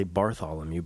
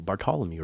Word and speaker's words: Bartolomeu: I want to say Bartholomew, but it's spelled B-A-R-T-O-L-O-M-E-U. Bartholomew, right? Right.